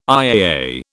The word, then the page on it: IAA: British English